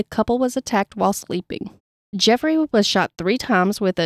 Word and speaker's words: Geoffrey: Geoffrey, G E O F F R E Y. I'm going to say Jeffrey. Hood and Molly LaRue.